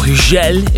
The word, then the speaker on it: Hugel: Hugel, or Hugel if you're French and you say it right, with Ultranate, Moving to the Sun.